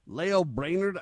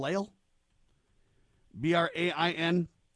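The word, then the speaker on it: Lael Brainard: Leo Brainard, I guess is how you say the name. L-A-E-L, Leo. B-R-A-I-N-A-R-D, Leo Brainard.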